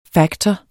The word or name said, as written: factor